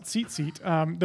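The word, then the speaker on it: tzitzit (ציצית): No, I don't speak Hebrew all the time, but that's how I'm told it's pronounced, tzitzit.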